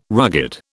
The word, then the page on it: rugged: British English